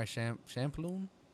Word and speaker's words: Champloo: Even some old ones like Cowboy Bebop, Samurai Champloo. That's how you pronounce the last part? – There's no N in it, but yes.